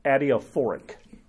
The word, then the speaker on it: adiaphoric: It is things that are called adiaphoric, five syllables. I'm really only capable of three, but I'm stretching to five. Adiaphoric.